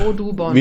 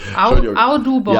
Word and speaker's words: Audubon: A-U-D, A-U-D und dann U. Und dann B-O-N, hätte ich gesagt. Audubon. – Wie man spricht. – Audubon. – Ja, genau. – Audubon.